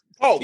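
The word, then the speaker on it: Palk: How many times you gonna say that young man's name wrong? – I know. – You just said how many goddamn times? It's Pluck. – Polk. P-A-L-K. P-A-L-K. Where the fuck is the L coming from? – You just...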